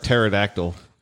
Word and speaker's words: pterodactyl: I'll try to figure out how to work pterodactyl because the P is silent.